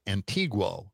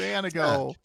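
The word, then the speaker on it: Antigo: Tim was saying Antigua. – Oh, no. Oh, no, not the Caribbean island. No, it's Antigua.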